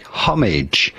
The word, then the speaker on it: homage: There's essentially three different ways. The British pronunciation is something like homage. Homage.